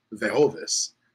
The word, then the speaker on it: Vejovis: The correct pronunciation is actually Vejovis. And you use the J as in, like, Spanish.